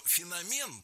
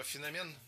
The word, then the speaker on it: феномен: Дело в том, что в эталонном словаре, рекомендованном Министерством просвещения, написано, что у нашего слова два значения. И от значения зависит ударение. Феномен – это научный термин.